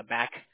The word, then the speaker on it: BAC: And how often do you hear from your local union regarding legislative and regulatory updates that are important to the back Never. Once a year, a couple of times a year, or okay. Here's how you pronounce that. It's BAC. It's not Beck.